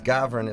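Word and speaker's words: Gawron: Like another character altogether, maybe a mix between Lebowski and Bukowski, but, you know, Gavronsky, what it means, Gavron is a Polish last name, and of course over here we pronounce it with the W, and it means a blackbird.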